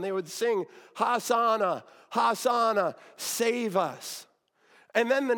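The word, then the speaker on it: Hosanna: That word save us would have been in Hebrew pronounced has ana. Has ana.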